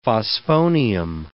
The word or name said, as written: phosphonium